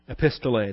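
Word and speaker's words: Epistolae (ἐπιστολή): Epistolae, or Epistolae, I guess, accent on the last ada.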